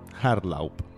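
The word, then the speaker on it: Hartlaub: Llavors, el seu impulsor va ser Gustav Friedrich Artlau, no sé si ho pronuncio bé, segur que no. – L'H era... una mica aspirada. – Bueno. – Artlau.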